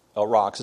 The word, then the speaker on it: aurochs: Aurochs. It's spelled A-U-R-O-C-H-S, aurochs.